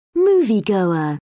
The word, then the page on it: moviegoer: British English